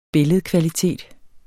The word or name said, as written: billedkvalitet